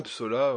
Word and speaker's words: Psola: Ouais, Psola, ouais, Pso. – Et ça s'écrit P-S-O-L-A.